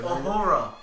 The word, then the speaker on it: Uhura: Uhura? I can never pronounce her name. – Uhura.